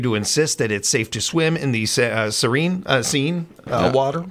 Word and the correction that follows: Seine: It's, oh, the Seine, yeah.